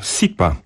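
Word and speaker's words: Cipa: No, następny artysta, miał tutaj problemy na początku z nazwiskiem, ale okazuje się, że chyba nie będzie jakichś większych sensacji, bo nazwisko czyta się po prostu Sipa.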